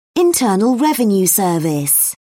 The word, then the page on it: internal revenue service: British English